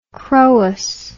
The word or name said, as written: chroous